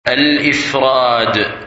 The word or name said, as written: Ifrad